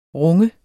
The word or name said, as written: runge